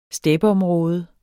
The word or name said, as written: steppeområde